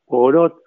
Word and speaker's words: Poirot: Mira, yo, las películas que más me gustan son las de Sherlock Holmes, la de Monsieur Poirot, más o menos así se pronuncia en francés.